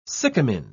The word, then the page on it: sycamine: British English